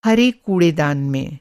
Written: कूड़ेदान

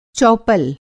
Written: चौपल

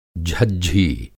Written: झज्झी

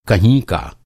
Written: कह

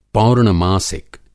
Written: पौर्णमासिक

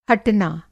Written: हटना

